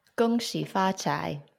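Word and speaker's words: Gong Xi Fa Cai: Gong Xi Fa Chai, or in Cantonese, Gong Hei Fa Choi.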